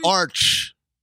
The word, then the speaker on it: arch: Arch. Like an archway.